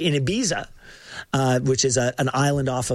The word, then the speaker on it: Ibiza: So it's Ibiza. It's a Z. I say it like a Z.